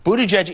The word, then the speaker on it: Buttigieg: Buttigieg is a Maltese name. It means owner of chickens. – All right, so you pronounce it boot, B-O-O-T, edge, edge.